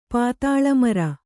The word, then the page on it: ಪಾತಾಳಮರ: Kannada